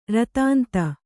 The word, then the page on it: ರುದಂತಿ: rudanti